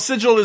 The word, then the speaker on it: Sigil: Well, Sigil is a word that means something already. Mm-hmm. It evokes that magical feel. And G before an I is a soft G. I'm sorry. There's just rules.